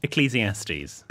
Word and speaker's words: Ecclesiastes: So it's pronounced Ecclesiastes. – Ecclesiastes? – Yes. – Ecclese... – Jordan's writing the phonetic pronunciation. – Ecclese... – Ecclese as in John Cleese.